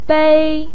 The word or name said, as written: bei1